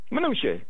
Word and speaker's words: Mnuchin: They say his name is Mnuchin. I can't say it like that. It's hard. It's just Mnuchin. Mnuchin, yeah, there you go.